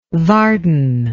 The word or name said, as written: vardon